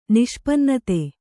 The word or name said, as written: ನಿಷ್ಪನ್ನತೆ